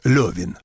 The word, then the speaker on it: Левин: Борис Михайлович, вы произнесли имя толстовского героя как Левин, но пишется во всех изданиях без точек над «е», без этих ежек, столь любезных Солженицыну. – Эхенбаум говорит, что писать и произносить следует Левин.